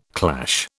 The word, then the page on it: clash: British English